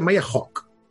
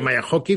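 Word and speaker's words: Hawke: Al final, ella mateix pronuncia Maya Hawk. – Sí? Ella, sí. Jo, jo deia Maya Hockey fins que vaig escoltar amb ella que diu, no, no, Maya Hawk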